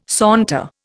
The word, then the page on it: saunter: Indian